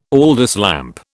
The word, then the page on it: Aldis lamp: British English